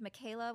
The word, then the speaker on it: Michaela: And so I just got a lot of, I spell it Michael with an A on the end, so M-I-C-H-A-E-L-A. So I got a lot of just straight Michaels, people who didn't see the A, or Michiela.